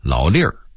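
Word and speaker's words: 老论儿: 北京话里有个词儿老粒儿，这个粒儿啊您要读成论可就露怯了，但写得写成论文的论，北京话一定要读成老粒儿，意思是老年间流传下来的说法和规矩，但它实际上是变音字。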